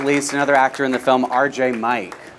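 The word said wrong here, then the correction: Mitte: Mitty. – It's Mitty.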